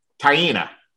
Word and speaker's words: Taina: Taina.